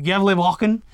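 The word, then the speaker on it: Gävlebocken: We have two updates for you regarding this year's War on Christmas, and first up is the rollout of the Gavel Goat. how do you say it correctly? Gobble? – Yevlegoat. – Yevlegoat, or the Yevlevaken of Sweden.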